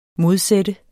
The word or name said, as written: modsat